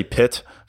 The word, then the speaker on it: pyt: And it's a Danish word, to my point in the intro, and it's spelled P-Y-T. and apparently it's like pyt or something close to that. I'll just say pit for this episode, but know that I recognize my pronunciation is probably a bit off.